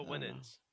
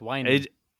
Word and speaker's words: Winans: No, Mario Winans. – Mario Winans, yes. – Is that the one that got in trouble? – I think it's pronounced whining.